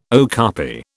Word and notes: okapi: British English

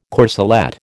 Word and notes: corselette: American English

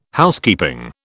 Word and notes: Housekeeping: British English

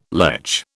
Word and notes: lech: British English

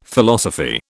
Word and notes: Philosophy: British English

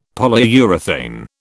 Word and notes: Polyurethane: British English

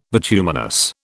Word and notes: bituminous: British English

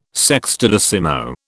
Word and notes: sextodecimo: British English

